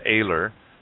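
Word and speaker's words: Oehler: And so his last name is spelled with an O, O-E-H-L-E-R, but it's pronounced Ehler.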